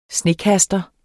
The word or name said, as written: SNEKASTER